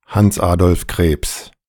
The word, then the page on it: Hans Adolf Krebs: ˈhans ˈaːdɔlf ˈkʁeːps; German